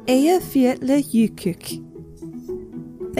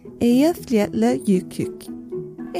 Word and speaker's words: Eyjafjallajökull: The volcano Eyjafjallajökull. Thank you, Iceland. For anyone who's curious and want to try to pronounce that themselves, it's spelled E-Y-J-A-F-J-A-L-L-A-J-O with umlauts K-U-L-L. Eyjafjallajökull.